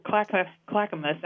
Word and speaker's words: Clackamas: You have in Oregon, I think it's Clackamas. I'm going to try to say that right. – That's pretty good. I'm from Washington State. That was a pretty good pronunciation.